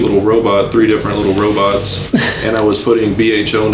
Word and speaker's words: Robhots: So I just went ahead and put BHO into the word robot, and that's where you get the spelling R-O-B-H-O-T-S. So I've always said the H is silent, but I've also said as long as you eat the gummies and like them, I don't care if you call it robots, Robhots, whatever it is, as long as you like Robhots.